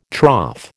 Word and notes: trough: American English

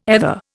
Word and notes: ether: Indian

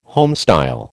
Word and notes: homestyle: American English

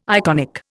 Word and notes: iconic: Indian

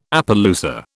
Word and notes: Appaloosa: British English

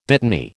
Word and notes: dittany: American English

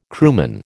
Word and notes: crewman: American English

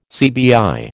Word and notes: CBI: American English

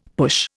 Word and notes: push: Indian

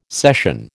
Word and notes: cession: American English

